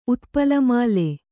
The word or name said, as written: ಉತ್ಪಲಮಾಲೆ